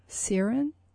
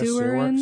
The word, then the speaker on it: Seweryn: It's S-E-W-E-R-Y-N, Seryn. – S-E-W-E-R-Y-N. – That's the first name. – Yeah, I guess Seryn. – Seryn.